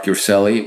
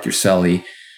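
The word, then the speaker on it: Urselli: I'm on all the social media channels with my first and last name, which is Mark Yurcelli, which is spelled M-A-R-C, no K, and then U, R like Robert, S like Sam, E-L-L-I. So it's Mark Yurcelli on Instagram, on Facebook, on LinkedIn, on Twitter, and on YouTube, although I'm not very active on YouTube.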